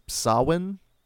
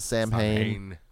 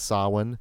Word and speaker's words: Samhain: Now, I know everyone says, now, technically it's Samhain, I believe, the correct pronunciation, but everyone pronounces it Samhain. I always said Samhain. – Me too. – There was the Glenn Danzig side band called Samhain, but apparently it's Samhain.